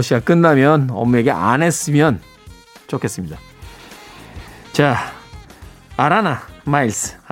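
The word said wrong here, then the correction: Alannah Myles: 아라나 마일스. 이 발음이 참 힘들어요. N자가 두 개 들어가 있는데 아라나 마일스, 네.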